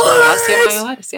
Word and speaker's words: Osteomyelitis: Osteomyelitis, yeah.